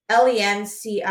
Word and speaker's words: Lencioni: So anyways, I started, if you guys haven't heard of the book, The Ideal Team Player by Patrick Lens. Oh gosh, I'm going to butcher his name. It's Lencioni. It's How to Recognize and Cultivate the Three Essential Virtues.